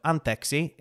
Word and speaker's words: anteeksi: I have also recently learned, so anteeksi is how you say sorry.